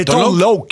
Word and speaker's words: Tone Loc: Ça a l'air qu'on ne prononce pas bien, là, on nous a dit ça au 6-12-12. – C'est Tone Loc? – C'est Tone Loc qu'il faut dire.